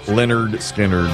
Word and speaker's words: Lynyrd Skynyrd: 53 years ago today, Lynyrd Skynyrd releases this album with Freebird on it, pronounced Lynyrd Skynyrd.